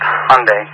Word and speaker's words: Hyundai: It's pronounced Hyundai, and it's going to be one of the same.